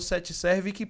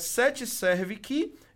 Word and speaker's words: 7 Servic: Gente, eu falo 7 Servic porque o C é mudo, tá? Então é 7 Servic elevadores, no plural, e depois o SJC de São José dos Campos e ainda no final SP.